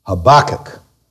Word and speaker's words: Habakkuk: Or Habakkuk, or however you want to pronounce it, the accent should be on the central syllable, Habakkuk. Habakkuk.